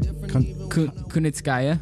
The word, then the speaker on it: Kunitskaya: Kunitskaya.